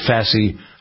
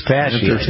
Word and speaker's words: fasciitis: We also talked about a new treatment for plantar fasciitis. How do you pronounce that? – Fasciitis. – Fasciitis. Yeah, right.